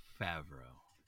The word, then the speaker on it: Favreau: Sorry, Favreau.